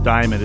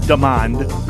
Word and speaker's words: Damond: I keep mistakenly pronouncing the victim's name, Justine Diamond. It's Damond.